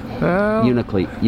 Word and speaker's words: Unalakleet: Unalakleet, Alaska. – Oh, did you say that right? – Yeah, I think so. – I've been leaving the middle L off. Unalakleet.